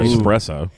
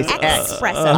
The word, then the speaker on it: espresso: Thank God for espresso. – Which most people mispronounce and say espresso, but it's not.